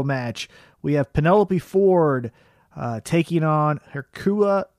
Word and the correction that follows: Hikaru Shida: Oh God, I'm so fucking bad. – Hikaru Shida. – Hikaru Shida. That's how you say it because I barely speak English.